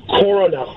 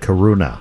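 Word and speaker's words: koruna: So, yeah, I've heard of crowns before, but what is the local pronunciation, the proper way to say the currency there? – The currency is Corona. – Corona. – Corona. I call it. Corona. I call it Karuna.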